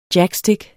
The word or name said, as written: jackstik